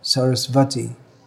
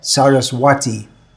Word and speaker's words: Saraswati: In Indian languages, there's no W sound. W and V are often interchanged, where wrongly interchanged. It's pronounced as a V, Sarasvati. If I was to read that as a native English speaker, I would read Saraswati.